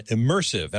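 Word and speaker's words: ImmersAV: immersive. And I must say, it's pronounced just like the word immersive, but it's spelled I-M-M-E-R-S, capital A, capital V.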